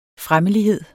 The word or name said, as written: fremmelighed